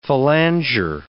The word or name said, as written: phalanger